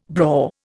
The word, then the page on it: braw: Indian